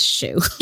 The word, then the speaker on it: shoe: All free, one baby show. No. – You? – Oh, God. It's pronounced shoe.